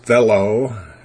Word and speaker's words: thelo: One root is thelo, T-H-E-L, long O, which means wish, will, or desire.